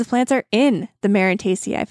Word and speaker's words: Marantaceae: Marantaceae. I had to look up how to pronounce that.